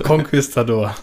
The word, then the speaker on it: Konquistador: Ähm, genau, und der war eben auch so ein Konquisitator und äh, Konquisitor. Ich habe Konquistador.